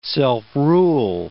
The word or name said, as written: self-rule